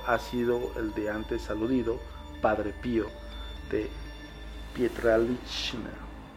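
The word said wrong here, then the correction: Pietrelcina: perdón, va de nuevo, Padre Pío de Pietralcina.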